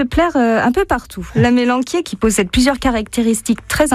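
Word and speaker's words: amélanchier: Et alors aujourd'hui on voulait parler de la mélanquier. Qui s'écrit à mélanchier. Mais il ne faut pas le dire parce que ça fait marrer les enfants.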